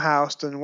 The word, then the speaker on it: Houston: And his name was actually spelled with two N's, H-O-U-S-T-O-N-N. When they named the street after him, they misspelled it. – Right. – But that's why it's pronounced Houston and not Houston. So it looks like it's Houston Street, but it's actually Houston.